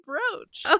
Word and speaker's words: brooch: It's pronounced brooch? Why wouldn't they put a fucking A in it then? I'm sorry. I'm not 107 years old. How am I supposed to know how to pronounce the word brooch? Oh, I hate you. Are you joking? Is that how you pronounce it? – Yes, that's how you say brooch.